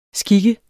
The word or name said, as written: skik